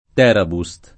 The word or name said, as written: Terabust